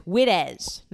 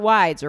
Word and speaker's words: Widdes: It's spelled Widdes, but it's Wittes, not Wides or Wids.